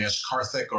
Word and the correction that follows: Akashic: or the Akarthic record, Akashic record, is that it's basically like a spiritual cloud where you download information.